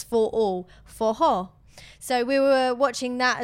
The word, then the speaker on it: forró: And actually, a cool fact about it, it's called forró and it's spelled F-O-R-R-O because their R's, when it's a double R, it's a ho sound with a H.